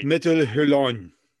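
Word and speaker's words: Métal Hurlant: And see, I've mentioned this before, because I remember giving the name and not being sure exactly how it's pronounced because it's French. And I Metal Hurlon. – That's close. Yes. That's, that's what I was going to say.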